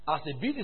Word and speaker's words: asebes: The word ungodly is the word asebes. Asebes is spelled A-S-E-B-E-S, asebes, which is the word used for one who is wicked.